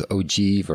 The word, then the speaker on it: ogive: I wasn't sure if it was ogive or ogive or whatever, but it's ogive.